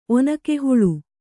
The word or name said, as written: ಒನಕೆಹುಳು